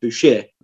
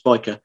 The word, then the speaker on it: Bashir: So the SH in that word gets turned to a CH. So the pronunciation became Bashir in the French spelling. There's a bit of an argument, I suppose, or more of a technicality, really, that when the name becomes Latinized, you stick with the Latin pronunciation, which would mean the CH becomes a K, so it's biker.